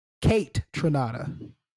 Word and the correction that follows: Kaytranada: Kate, like K, Tronada. Yeah, K Tronada. Yeah, there you go.